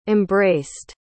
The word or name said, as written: embraced